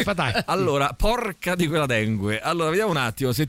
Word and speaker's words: dengue: Dengue, dengue. Ma non è vero mai, ragazzi. Dengue, si dice dengue.